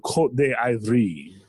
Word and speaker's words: Côte d'Ivoire: Or Brian, as they like to be called, Côte d'Ivoire. – Yes. – Oh, that's the French pronunciation.